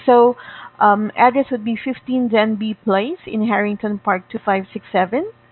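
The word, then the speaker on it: Denbigh: Denby. It's spelled D for dog, E-N-B-I-G-H, Denby Place, Harrington Park.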